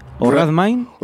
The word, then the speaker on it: Redmayne: Bueno, vamos a hablar del premio a mejor actor, que recae en Eddie, a ver si lo digo bien, Redmayne, se dice. Redmayne.